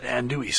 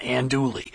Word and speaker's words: andouille: Yeah, I like mine with that andouille sausage. Yeah, that's right. Andouille, that's how you say it, not andouille.